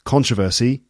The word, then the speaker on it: controversy: it's not controversy, it's controversy. That's the proper way of doing it. If you look on the Cambridge Dictionary website, they list this with the American pronunciation like this. – Controversy. – Controversy with the stress on the first syllable, and in the UK, controversy. I mean, the Cambridge Dictionary's version is...